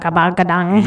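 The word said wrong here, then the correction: Khruangbin: It's actually, I found out the way to pronounce it. – Okay. – It's Kruang Bin. – Okay. – Kruang Bin is the name of the band.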